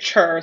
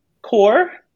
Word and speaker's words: Chur: The town, it's, I say it Chur, C-H-U-R, but I know it's pronounced more like core. It's pronounced like core with a soft O.